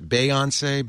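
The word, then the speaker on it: Beyoncé: They got the exclusive on Beyonce's, how do you pronounce that? Beyonce. Thank you. – It's Beyonce.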